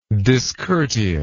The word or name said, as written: discourteousness